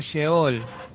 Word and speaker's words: Sheol: Y tiene una Lamed con la que al tener estas letras, se pronuncia como Sheol. Se pronuncia como Sheol. Es una palabra totalmente hebrea.